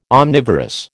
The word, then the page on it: omnivorous: American English